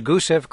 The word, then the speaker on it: Gusev: And forgive if, and the Martians who might be listening in 1,000 years, please forgive me any mispronunciations. This is the Gusev Crater and the Meridiani Plenum. – Well, your pronunciations were perfect. Gusev Crater is where a Spirit's going to land.